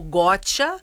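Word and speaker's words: Gottsha: Então ficou Gotia, como o americano fala, I got you,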